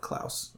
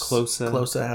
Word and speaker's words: Klose: Miroslav Klaus. Or Klaus. – Klose, however you say it. – I've heard it pronounced both ways.